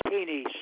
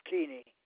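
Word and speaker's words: Kuehne: My first name is Richard, R-I-C-H-A-R-D. – Okay, last name? – Keeney, spelled K-U-E-H-N-E. K as in kettle, U-E, H as in hat, N as in nest, and E, K-U-E-H-N-E, pronounced Keeney.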